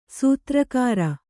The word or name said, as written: ಸೂತ್ರಕಾರ